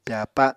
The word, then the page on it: pyàpa: Apatani